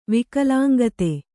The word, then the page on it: ವಿಕಲಾಂಗತೆ: Kannada